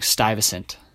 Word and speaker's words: Stuyvesant: I said that right. I've been practicing Stuyvesant for a long time here now.